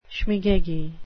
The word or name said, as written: schmegeggy